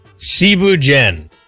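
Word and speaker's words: thiéboudienne: All right, first off, Cebu Jen. It's spelled like Thibu Dien.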